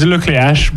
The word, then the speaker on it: Le Cleac'h: It's Le Cleac'h, but like, I'll give you that. Okay. Like, most of the English people can't say my name properly, so.